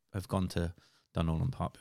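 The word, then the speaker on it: Dunorlan Park: Dunloran Park.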